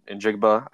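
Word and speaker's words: Njigba: He had Jackson Smith and Jigba, is how you say it.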